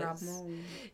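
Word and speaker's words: Ramón: You gotta put an emphasis. Ramon Rodriguez. – Ramon.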